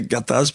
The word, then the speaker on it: Gattaz: Gattaz, c'est toujours bien prononcé Gattaz puisque je suis né à Bourgoin, entre Lyon et Grenoble, dans l'Isère, donc dans le Dauphiné.